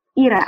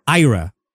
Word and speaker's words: Ira: My name is Ira. – How do you say it? – Ira, I-R-E. – Ira. – I-R-A. A. I don't know how to say it. – Ira. – Yeah, it's Ira.